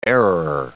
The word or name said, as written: airer